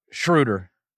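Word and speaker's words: Schröder: Is it Schroeder or Schroeder? I always mess this up. – Schroeder.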